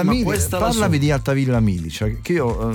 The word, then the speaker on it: Altavilla Milicia: Altavilla Milicia si dice. – Milicia. – Milicia, te lo dico proprio con l'accento.